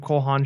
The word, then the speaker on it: Cole Haan: Am I saying that right? – No, Cole Haan, you're right.